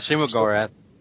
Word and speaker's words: Shuma-Gorath: This one, the full team finally comes together to bring down Shuma-Gorath, if I'm pronouncing that right. – Works for me. – Yeah, Shuma-Gorath.